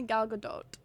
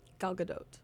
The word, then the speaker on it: Gal Gadot: I've heard it pronounced different ways. I think it's technically Gal Gadot, right? – Gal Gadot.